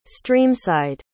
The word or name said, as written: streamside